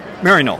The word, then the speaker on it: Maryknoll: Maryknoll? How do I say that? – Maryknoll.